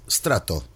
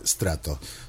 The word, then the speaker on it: Stratto: Hola amigos, aquí estamos en una nueva emisión de Onda Sonora, hoy con un nuevo invitado, cuyo nombre es Strato. ¿Es correcto eso? Lo dije bien. – Sí, Strato. Doble T final, Corporation. – Estamos con dos T, ¿sí? Dos T al final, Strato.